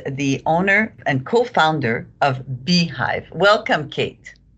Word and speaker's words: Bhyve: So why don't you tell us a little bit, Kate, about your company called Beehive, which is spelled, just for fun, Bhyve.